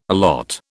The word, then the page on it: allot: British English